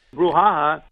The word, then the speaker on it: brouhaha: Brouhaha. – It's a brouhaha. Stress on the ha-ha.